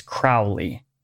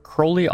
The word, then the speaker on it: Crowley: However, you can pronounce it any way you like, and Crowley often rhymed his name with the word holy in his diaries and poetry.